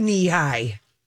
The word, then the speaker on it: Nehi: And the soda company was a play on the word knee, and it was spelled instead of K-N-E-E, it's spelled N-E-H-I. – Yeah. Anyhow. I had a knee high.